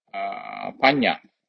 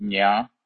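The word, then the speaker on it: paññā: Pronounce P-A-N with diacritical mark tilde on top, so it's pronounced Nya, and again Panya, P-A-N with diacritical mark tilde on top, repeat A long A Panya.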